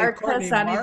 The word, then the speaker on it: Marchesani: It's a hard CH, so it's okay, Marchesani because it's Italian. Yeah, it's like Chianti.